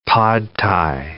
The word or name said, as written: pad thai